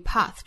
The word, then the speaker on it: path: 那第二句的 path to path, 注意 path 这个词哈，在这个词之中呢，字母 a 英音读成 r, 后元音的 r, path, path, from path to path, no man in sight.